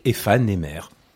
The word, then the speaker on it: Aephanemer: Enfin bref, il faut prononcer les mots comme on les prononcerait normalement, fané et éphémère, donc éphané-mère.